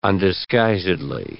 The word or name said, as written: undisguisedly